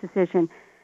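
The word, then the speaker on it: Dacyczyn: But the authority to go to on this is Amy Decision, and her last name has some bizarre spelling that involves C's and Y's, but it's pronounced Decision.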